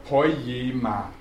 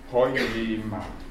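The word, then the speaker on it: Poiema (ποίημα): Betonung auf natürlich auf dem Jota. Poiema. Poiema.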